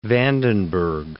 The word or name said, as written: Vandenberg